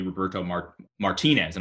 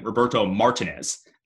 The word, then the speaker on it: Roberto Martinez: For example, Everton's old manager used to be Roberto Martinez, and I almost just said the wrong way because they used to call him Roberto Martinez. That's not his name, guys.